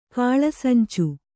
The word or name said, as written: ಕಾಳಸಂಚು